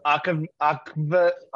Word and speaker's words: aquavit: Because Aquavit is how it's like actually pronounced, is just spiced vodka, typically from Carway and Gilstead.